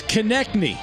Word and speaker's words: Konecny: And from the Flyers, Travis Konecny. – Wow. – Am I right on that? – Yeah. You. – I'm Mr. Hockey, you're 3-for-3 in your pronunciations.